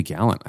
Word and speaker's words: Gallant: It should be Gallant, I think. – It should be? Okay. – I mean, it's spelled Gallant. And Gallant is defined by being gallant.